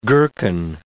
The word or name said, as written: gherkin